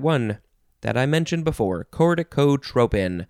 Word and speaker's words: corticotropin: you can spell it, oh, instead of tropin, you can pronounce it and spell it trophin with a PH. Although, is it, you know, it's not a pronunciation. No, it is. Oh my God, I'm so sorry. Corticotropin or corticotrophin with a F.